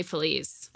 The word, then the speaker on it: Feliz: Is it Los Feliz? – No, it's definitely Feliz.